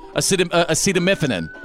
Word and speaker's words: acetaminophen: These were a prescription bottle with a label that said hydrocodone acetaminophen.